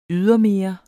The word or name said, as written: ydermere